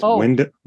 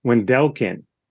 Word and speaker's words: Wendelken: I learned how to pronounce his last name. It's Wendelken. – Wendelken. He said there's an accent in there. Yeah, he's Wendelken. From the South too.